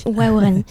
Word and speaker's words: Waorani: Et par exemple, chez les Waorani, je ne me trompe pas dans la prononciation. – Waorani.